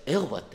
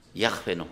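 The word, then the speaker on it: ヤハウェ: しかも私は主であり、ね、この主っていうの太文字で書いてある主っていうのは原文でヘブル語の 4 文字、ヤーフェとか発音されると思うんですね。昔はエホバって発音してたんですが、ヤフェの方が正しいと思うんですが、まあでも発音は分かんなくなってんで、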